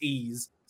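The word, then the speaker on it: Ys: So Resident Evil 7, Biohazard Gold Edition, SpongeBob SquarePants, Battle for Bikini Bottom, Rehydrated, Eve's, Ease 8, Lacrimosa of Dana, and they even told us in the post it's pronounced Ease.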